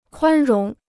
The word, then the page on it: 宽容: kuān róng